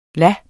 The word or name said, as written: las